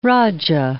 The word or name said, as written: rajah